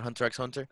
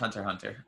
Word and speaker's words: Hunter x Hunter: Is it not Hunter x Hunter? – No, it's Hunter Hunter. – Uh, okay. Uh, you're not supposed to pronounce the X.